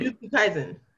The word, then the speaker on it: Jujutsu Kaisen: Jujutsu Kaisen, if I'm saying that right. – Jujutsu Kaisen.